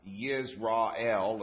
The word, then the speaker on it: Yisrael: And according to Strong's, it says that this Israel, and they use a Y to do it, not an I. So it's pronounced Yisrael, although what they call it.